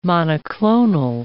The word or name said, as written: monoclonal